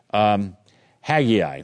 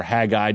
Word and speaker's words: Haggai: Haggai, or Haggai, depending upon how you want to pronounce it.